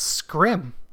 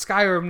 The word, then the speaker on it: Skyrim: I wasn't on board because I was pronouncing it. What's Scrim? – you and my sister-in-law say Scrim. – Well, I mean, I know it's Skyrim now, but, you know, it's just...